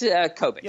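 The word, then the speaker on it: Kobik: Is it Kobolik? – It's Kobolik. – Yeah, Kobolik, excuse me.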